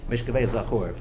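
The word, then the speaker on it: Mishkevei Zachur (משכבי זכר): Instead of the real explanation, the real translation of the word, how do you pronounce it? Mishkevei. What's the next word? – Sure. – Zachur. Mishkevei Zachur.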